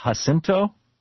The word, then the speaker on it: San Jacinto: And he rode through the San, I think it's pronounced Jacinto, J-A-C-I-N-T-O, the San Jacinto Mountains National Monument.